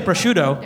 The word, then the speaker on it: prosciutto: I just want to applaud for that pronunciation of prosciutto.